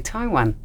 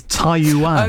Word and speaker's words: Taiyuan: How do you pronounce that, Andrew? – Taiyuan. – Taiyuan. Do you always just say Taiwan? – Is it the same? It's not the same place.